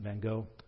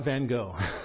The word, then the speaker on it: van Gogh: We apparently mispronounce his name. You'll hear it the way the Brits say it here in a second. It's a Dutch name, so it sounds different than our anglicized version, but we're going to go with Van Gogh.